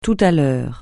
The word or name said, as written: tout à l'heure